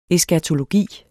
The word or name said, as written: eskatologi